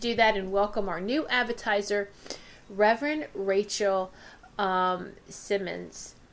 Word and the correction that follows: Simons: I said that wrong. Simons, isn't it, Wendy?